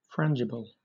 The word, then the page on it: frangible: British English